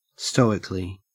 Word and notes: stoically: Australian English

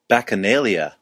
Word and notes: bacchanalia: American English

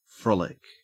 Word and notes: frolic: Australian English

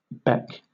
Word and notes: beck: British English

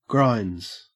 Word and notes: grinds: Australian English

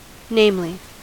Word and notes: namely: American English

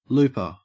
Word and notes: looper: Australian English

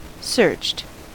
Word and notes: searched: American English

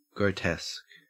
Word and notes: grotesque: Australian English

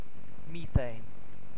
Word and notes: methane: British English

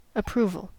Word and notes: approval: American English

